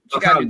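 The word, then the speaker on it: Ōkami: How you say it? – It's weird how you pronounce it. It started with Okami. Okami. – There you go.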